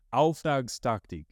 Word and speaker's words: Auftragstaktik: Auftragstaktik.